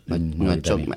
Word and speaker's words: Nouakchott: Y Nouakchott. – Nouakchott.